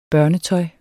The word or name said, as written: børnetøj